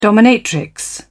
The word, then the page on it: dominatrix: British English